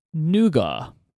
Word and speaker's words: nougat: So I would say this word is nougat. – It's nougat. – You would say it is nougat.